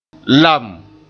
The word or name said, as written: lam